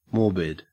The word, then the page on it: morbid: Australian English